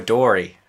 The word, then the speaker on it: Midori: I did some Maduri. – Wipeout out of the bottle. I was drinking Kahlua. – Is it Maduri or Midori? It's Midori.